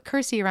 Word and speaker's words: Kirstie: So I used to say, oh, Kirstie rhymes with thirsty, you know?